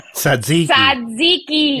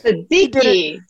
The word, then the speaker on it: Tzatziki: It is a word, but shall I correct your pronunciation, Spike? – Really, this is why we get hate mail. – Tzatziki. – Yes. – Tzatziki.